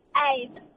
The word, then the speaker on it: Abe: Have I said that name right? – No. – Tell me how you say your name. – Abe. – Abe. There you go. Probably should just see how it looks on the screen. I should just said it like that.